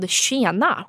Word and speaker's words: Tjena: So as an example, there was like this Tupperware line called Tjena, which is how you say like hey in Swedish.